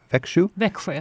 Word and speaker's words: Växjö: And also, they've done a lot to help Americans reconnect in Växjö. – Växjö. – Yeah. Mm-hmm. It's spelled V-A-X-J-O, mm-hmm. Växjö.